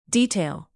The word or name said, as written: detail